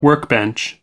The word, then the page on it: workbench: American English